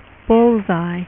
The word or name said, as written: Bullseye